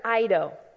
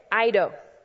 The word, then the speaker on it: oida: And that other Greek word is the word eido. It's spelled O-I-D-A if you're one that likes to write it down. O-I-D-A, eido. And here's what eido means.